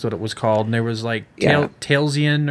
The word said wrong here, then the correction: Taliesin: And there was Taliesin.